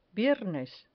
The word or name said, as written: viernes